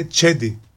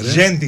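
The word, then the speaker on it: Cedi: Και το τσεντί που λέμε εμείς είναι τσέντι. Τζέντι είναι κανονικά. Είναι τζέντι Οσμάν.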